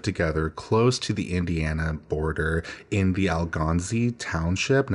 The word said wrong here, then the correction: Algansee: Now, I apologize if I got that wrong. That is Algansee, and I looked up the pronunciation, but they seem to live a pretty comfortable life on, you know, a pretty decent piece of land with their three children.